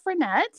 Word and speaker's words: Frenette: Well, my name is Cynthia Frenette. So my last name is spelled F-R-E-N-E-T-T-E.